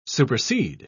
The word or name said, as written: supersede